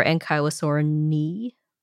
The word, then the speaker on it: Ankylosaurinae: And they found Panakosaurus to be the most basal member of Ankylosaurinae. – Okay. – Or Ankylosaurinae, according to the pronunciation you found recently. – You do the E's instead of A's.